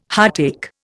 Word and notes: heartache: Indian

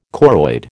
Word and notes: choroid: American English

